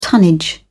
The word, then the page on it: tonnage: British English